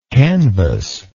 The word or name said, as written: canvasser